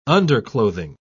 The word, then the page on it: underclothing: British English